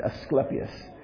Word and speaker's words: Asclepius: They had a hospital of Asclepius. I'm sorry. I want to say this right. Asclepius.